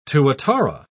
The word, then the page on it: tuatara: British English